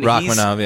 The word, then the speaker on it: Rakhmonov: I can't really pronounce his last name. Ro- Rakhmonov or something. – Rakhmonov, yeah. Yeah, like a I want to say he's one of those guys.